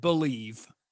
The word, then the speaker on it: BLEAV: We pronounce it Believe, but BLEAV. We take out some letters just because why wouldn't you?